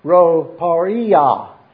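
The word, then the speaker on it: roparia (ῥυπαρία): This word is unique in that it is the Greek word roparia. And I've written it out for myself so that I could pronounce it correctly. It's H-R-O-O, is the way you pronounce it, P-A-R-E-E-A-H.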